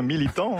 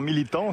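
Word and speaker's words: Militão: Comment on prononce Militao ou Michao? – Eh bien, franchement, Jimmy, il s'en est très bien sorti, hein. Militant, hein. Eder Militant, si on veut exagérer un peu la prononciation, mais il était bien dans le ton.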